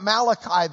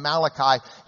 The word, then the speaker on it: Malachi: Although that's not the way he would have pronounced his name if you'd called him Malachi, he'd have looked at you like you were from Lubbock. Um, the Chi ending in English is key in in in Hebrew, so it um, Malachi but uh, we're going to call it Malachi because otherwise you won't know what I'm talking about.